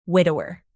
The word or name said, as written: widower